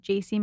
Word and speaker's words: Jaci: It's just Jaci Marie Smith, and my name is spelled J-A-C-I.